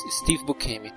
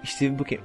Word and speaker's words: Steve Buscemi: Steve Buscemi. Steve Buscemi. Nossa, sua pronúncia foi tão perfeita. – Tá, pra, pra verdade, nem sei se está certo. É Steve Buscemi mesmo.